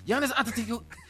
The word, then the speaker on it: Giannis Antetokounmpo: Giannis Antetokounmpo. The Greek Freak is going to stay in Milwaukee. That's all it is. Giannis Antetokounmpo.